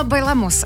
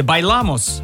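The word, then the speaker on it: Bailamos: Представляете, они вообще не говорили по-испански, неправильно расставили ударение. И в слове «Байламос» оно должно было вот падать. На второй слог, а не на последний.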